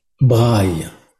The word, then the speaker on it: braille: My train of thought was thinking about Braille in the sense of how is it pronounced in français, sorry? – Braille. – Braille. I'll remember that.